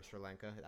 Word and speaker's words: Sri Lanka: have our thoughts and prayers for Sri Lanka.